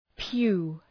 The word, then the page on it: pew: pju: